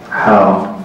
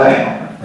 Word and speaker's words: حا: لكن كلا الإسنادين يلتقي في شيخ واحد غالبا أو في صحابي واحد، وينطق بها أثناء القراءة حاء هكذا كما قال ابن الصلاح، يعني الحاء معها ألف، يعني إذا ما قلنا مع الألف ستنطق بها كيف؟ لا، إذا قلنا بإلغاء الألف ستنطق بها أح، هذا خطأ.